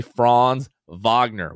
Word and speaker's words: Franz Wagner: But they certainly don't know how to say Franz Wagner, which is the correct pronunciation of his name.